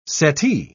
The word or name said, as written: settee